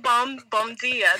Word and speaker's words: bom dia: I'm like, why would you learn how to say bum? Are there a lot of homeless people in Portugal? I'm confused. – No, bom dia. That's how you say it.